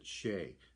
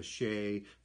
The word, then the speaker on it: sidhe: Some people pronounce it Shay, which is the same pronunciation as the Shay from the Tuatha De Danann, and the spirits of the Shay. And that the Shay is also a portal where the demon spirits would move back and forth through, and that's spelled S-I-D-H-E.